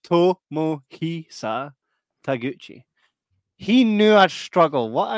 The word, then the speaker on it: Tomohisa Taguchi: Right, so it's Tomohisa Taguchi.